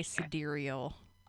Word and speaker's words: sidereal: Sidereal or side reel. It's spelled side reel. That's how it's spelled. – Okay. – But I think you say sidereal. I could be wrong. – Nope, you're right.